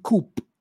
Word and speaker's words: coup: The word C-O-U-P, coup, is a French word. So in French, you say coup, you don't say coup. But yeah, if you were to go with the British pronunciation, it should be coup. But many of these words in the English language, they come from the French language. And yeah, so that's why we say coup, K-O-O, coup.